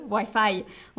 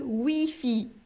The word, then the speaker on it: Wi-Fi: On ne dit pas le Wi-Fi. On dit le Wi-Fi.